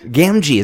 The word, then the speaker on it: Gamgee: Gamgee, is that how it's pronounced? – Yes.